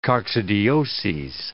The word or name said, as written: coccidioses